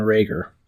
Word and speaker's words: Reagor: I'm going to say there's like a 55-yard touchdown pass to Rigor. I hope I'm saying his name right. And I think I'm going to take the over here, even though my gut tells me not to. – Well, I believe it is Jalen Reagor.